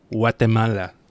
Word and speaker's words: Guatemala: I love Guatemala because you say it's with such a soft G that it's like Guatemala.